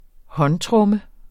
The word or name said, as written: håndtromme